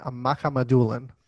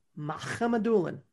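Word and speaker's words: Mukhamadullin: It's Shakir Makhamadoulin. Shakir Makhamadoulin. – Makhamadoulin. – You stole it. I was going to say it, and I was going to say it right.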